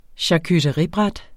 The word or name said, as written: charcuteribræt